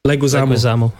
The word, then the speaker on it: Leguizamo: Leguizamo, I believe.